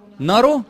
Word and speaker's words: Narrow: مارو ايه؟ نارو. لا يبقى انا يبقى النطق خدعني. – N A R R O W.